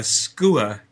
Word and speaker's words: skua: Uh, we don't have a skua category because skua would be the only word in it. – Yes, and normally skua is uh, two syllables.